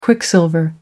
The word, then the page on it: quicksilver: American English